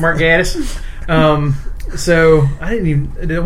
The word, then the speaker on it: Gatiss: I think the Brits say Gatiss. – Oh, Gatiss. – It's Mark Gatiss.